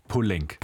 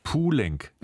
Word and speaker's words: Poulenc: Poulenc. – Poulenc. – Also, Poulenc. Also wie ein U und dann kann man wirklich so wie L-A-E-E-N-K. – Ja, Poulenc.